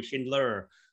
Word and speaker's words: Schindler: That's the Australian way of saying it. The American way would be Schindler.